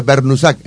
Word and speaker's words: Brnusak: este, entonces, pero se pronuncia Bernusac, es así. Lo podemos castellanizar así entonces.